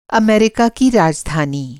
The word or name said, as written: अमेरिका